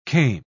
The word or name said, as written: kame